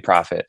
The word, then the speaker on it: Profitt: All my social media is my name, Tommee Profitt. And, you know, my name is spelled a little different. Tommee is T-O-M-M-E-E instead of a Y.